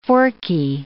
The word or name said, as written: forky